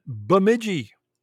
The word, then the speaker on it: Bemidji: In Bemidji, Minnesota. I'm saying that right, Alicia? – Yep, you are.